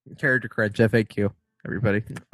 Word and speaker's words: FAQ: How do you pronounce it? – FAQ. – No, whatever. I say fact. – I know, but I used to too. – Yeah. FAQ. It's frequently asked questions.